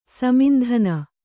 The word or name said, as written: ಸೀಮಂತಿನಿ